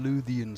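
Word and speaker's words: Lúthien: I want to say, if it's the one I'm remembering, it's Wolf Carving, which is, I believe, the Lurian Beryon. Lurian Beryon? – Luthien story. That's, yes, that's correct.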